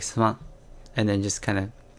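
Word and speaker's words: suman (すまん): And then for me it's also sorry or excuse me, it's suman.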